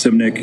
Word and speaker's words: Simnick: My name is Russ Simnick. It's spelled S-I-M-N-I-C-K.